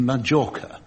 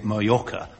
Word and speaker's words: Mallorca: The BBC, for a very long time, insisted very strongly on Majorca. They said, no, it's English words, major and minor, so it's Majorca and Minorca. But they finally yielded, and now the BBC is allowing us to say Mallorca, because that's what everybody calls it.